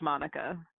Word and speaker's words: Maneka: It's actually spelled M-A-N-E-K-A. – Okay. I'll bite. – He says it's pronounced Monica. – I'll bite. How is it spelled? – M-A-N-E-K-A. – M-A-N-E-K-A.